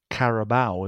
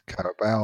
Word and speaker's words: Carabao: That's the way you pronounce it, isn't it? – I think it's Carabao, isn't it? – Caribou, isn't it? – No, it's Carabao, I think. I don't swear on it, but...